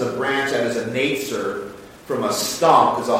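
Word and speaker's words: Netzer (נצר): In the Hebrew, the word for branch in Isaiah 11:1 is netzer. That's how you pronounce it.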